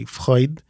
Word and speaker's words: Freud: But actually, Freud in German, which we say Freud, means joy.